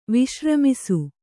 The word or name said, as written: ವಿಶ್ರಮಿಸು